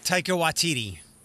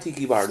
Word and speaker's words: Taika Waititi: I always call him Taika Waititi. – Okay, so not Tiki Bar. That's what I call him, and I'm not being ignorant. I just like, that's Tiki Bar.